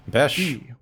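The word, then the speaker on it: Besch: Then Bobby Beesh. I should have listened to how this name was pronounced. Besh. – Besh.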